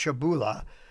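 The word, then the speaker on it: Przybyla: It's spelled P-R-Z-Y-B-Y. It's a Polish word. It's pronounced Shibula.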